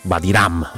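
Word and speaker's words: Wadi Rum: Vivi la magia del deserto del Vadirum, Vadiram, anzi si dovrebbe pronunciare.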